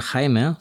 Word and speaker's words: Jaime: Und Jaime, Anna und Jaime, so wird es ausgesprochen, und Jerusalem wahrscheinlich auch Spanisch.